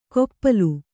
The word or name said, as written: ಕೊಪ್ಪಲು